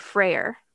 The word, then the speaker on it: Freyr: In Germany, there was an ancient custom of decorating evergreen trees with apples and nuts in honor of the Norse god Freyr. – Hmm. – I think that's how you say his name. – Yeah. Yeah. You don't have to roll the R.